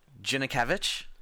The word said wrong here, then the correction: Yukinevich: Not even close. Yukinevich. – Yukinevich. Oh, okay. So I just I had the first consonant off.